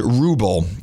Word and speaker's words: Vrubel: I believe it's pronounced Rubel. It's V-R-U-B-E-L. Vrubel?